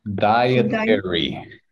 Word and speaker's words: dietary: Dietary. – Diary. Oh. – Not diary, dietary.